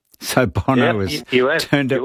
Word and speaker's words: Bono: Bono, thank you.